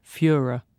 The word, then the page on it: fuhrer: British English